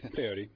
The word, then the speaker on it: peyote: And it's hard to, if you're not actually chewing peyote, it's hard to actually express those. Did I say that right? Peyote.